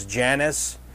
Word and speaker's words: Jannes: It continues and says, "Now, as Jannes," I pronounce it as Jannes, G-A-N-N-E-S. And some will say, well, Jannes would have been their pronunciation in Hebrew. Well, we don't know what their pronunciation would have been in Egyptian. So anyway, in our language, we call him Jannes and Jambres.